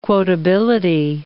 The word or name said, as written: quotability